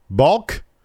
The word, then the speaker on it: balk: Balk? Do you pronounce the L or not? That balk. – It's like walk.